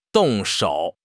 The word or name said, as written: 动手